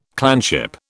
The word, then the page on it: clanship: British English